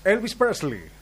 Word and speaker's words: Elvis Presley: ¿Puede pronunciar de nuevo? Porque al principio no dijo Elvis Presley, como si usted lo pronunció en inglés del sur profundo. ¿Cómo es? – Elvis Presley.